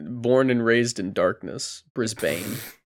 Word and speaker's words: Brisbane: Did you say Brisbane? – Is that what it's Brisbane. – Is it Brisbane? Melbourne. Brisbane. Brisbane. Sorry.